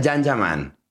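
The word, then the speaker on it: Can Yaman: Se escribe Jan Yaman, se pronuncia Jan Yaman.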